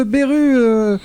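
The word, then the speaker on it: Beirut: Et ce titre de Beru, on prononce comme ça, Antoine ? – Oui, c'est ça.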